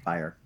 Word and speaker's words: Fryer: Am I saying that wrong? – Fryer. – With the late Sam Fryer. – Like fire with an R, as he was.